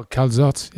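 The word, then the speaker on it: calçots: So slices of zucchini, slices of onion, red onion, especially green onions, like, you know, you were talking about with the calzots, is that how you say it? – Oh, calzots.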